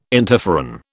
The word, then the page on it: interferon: British English